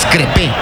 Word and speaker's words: Crépeau: Maxime Crépeau tiene la número 16, aunque también manifiestan de que la pronunciación es Crepe. – Crepe. – Nada más, sí. – Ahí los otros diptongos y hiatos no se pronuncian.